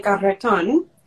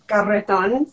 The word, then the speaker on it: Garreton: So yes, that is my full name, Chilean Garreton. So when I was a little girl, my dad, you know, sat me down and taught me how to roll my R so I could pronounce it yes.